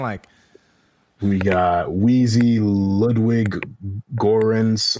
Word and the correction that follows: Göransson: I, I, don't know how to pronounce that. – Ludwig Göransson.